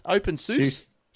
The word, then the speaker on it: openSUSE: how do you say that? OpenSUSE. And Fedora are coming to the Windows 10 store.